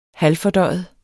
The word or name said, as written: halvfordøjet